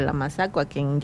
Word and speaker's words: mazacua: Entonces vamos a aprender a describir en Yocotán la boa o la mazacua, que se pronuncia mazacua.